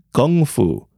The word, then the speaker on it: Kung Fu: First off, the proper pronunciation of Kung Fu is Gung Fu with a G instead of a K.